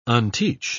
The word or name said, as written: unteach